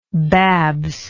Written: Babs

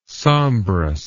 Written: sombrous

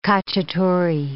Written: cacciatore

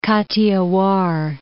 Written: Kathiawar

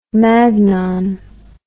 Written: magnon

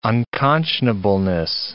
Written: unconscionableness

